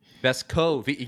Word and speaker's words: Vescovi: Vescovi, yeah, because I've seen there are videos of him saying his name, and he puts emphasis on the CO in the middle there.